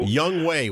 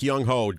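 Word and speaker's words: Younghoe: Young Wei, we pronounce. Greg, even though it looks like Young Ho, Greg said he pronounced Young Wei Koo.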